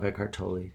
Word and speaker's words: Eckhart Tolle: Edcart Tolle. – Tolle. Okay, I it. Tolle, Tolle. – He calls himself Eckhart Tolle.